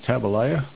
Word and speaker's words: Tabalaya: My SDF username is Tabalaya, rhymes with Jeremiah, kind of spelt the same way.